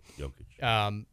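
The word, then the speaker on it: Jokić: I always say his name wrong. – Jokic.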